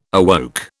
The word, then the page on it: awoke: British English